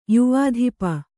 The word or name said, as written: ಯುವಾಧಿಪ